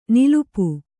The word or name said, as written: ನಿಲಿಪು